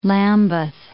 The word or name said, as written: Lambeth